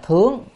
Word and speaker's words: thướng: Chữ này đọc là thướng, tại vì sao quý vị nào học là hán cổ đó, âm này có hai âm, một âm là thượng và thướng. Thượng có nghĩa là ở trên, còn ở đây á là phải đọc là thướng.